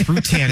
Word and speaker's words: Rutan: Dick Bhutan. – Yeah. – Dick Boutte? – No, it's Rutan is how you say it, guys.